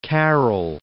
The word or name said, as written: Carol II